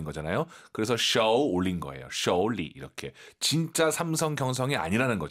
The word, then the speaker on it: 手里: 그리고 쇼가 아니라 오유는 어우입니다. 쇼우, 쇼리 꼭 기억하시고요. 쇼, 안 돼요.